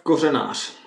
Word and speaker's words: Kořenář: And Josef, K-O-R-E-N-A-R. Do you know who I'm talking about? How do you pronounce that name? – Josef Kořenář. – See, Kořenář. – There's a lot of S's in a name that doesn't have an S. – An emphasis on certain syllables, too, that we always get wrong.